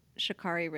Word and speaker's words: Sha'Carri: I am angry that um, Sha'Carri Richardson, I'm saying her name right mm-hmm.